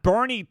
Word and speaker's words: Boerne: And thank you once again for the pronunciation guides that you are providing here on the show, because Bernie, Texas, B-O-E-R-N-E, is not how I would have pronounced it, but we appreciate you telling us how to pronounce it in the pronunciation guide.